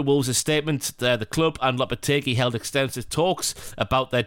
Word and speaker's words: Lopetegui: Have I said that right, Lopetegui? That sounds right in my head. – Let's go with it, yeah.